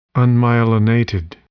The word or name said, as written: unmyelinated